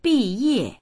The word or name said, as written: bi4ye4